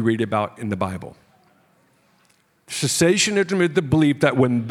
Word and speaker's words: cessationism: I'm going to spell it for you just so you don't think I'm saying sensationism. It's cessationism, C-E-S-S-A-T-I-O-N-I-S-M, cessationism.